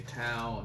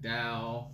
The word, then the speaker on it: Tao: I want to say Tao, and it's actually like Dao.